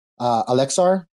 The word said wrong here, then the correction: Axelar: Is that the variation? – Yeah, you know, I have no idea how to pronounce that. – Like an axle for a car? – Axelar. There you go.